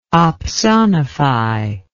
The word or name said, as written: opsonify